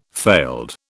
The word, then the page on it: failed: British English